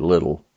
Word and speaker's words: Liddell: L-I-D-D-E-L-L. I would tend to pronounce it Liddell, but I think they pronounce it Little.